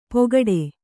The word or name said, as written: ಪೊಗಡೆ